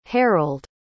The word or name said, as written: harrold